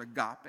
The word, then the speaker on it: agape: It's the word agape. If you were trying to spell it in English, A-G-A-P-E, agape, love.